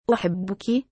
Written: uhibbuki

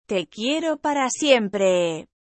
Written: te quiero para siempre

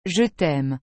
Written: je t'aime

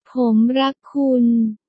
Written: pom rak kun

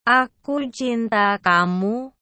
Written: aku cinta kamu

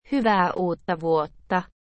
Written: hyvaeae uutta vuotta

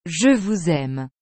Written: je vous aime

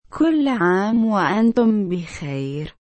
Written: kull am waantum bikayr

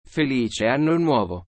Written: felice anno nuovo

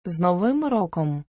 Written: z novym rokom

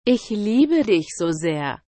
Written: ich liebe dich so sehr

